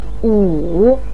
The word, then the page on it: wu3: pinyin